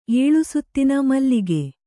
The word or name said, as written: ಏಳುಸುತ್ತಿನ ಮಲ್ಲಿಗೆ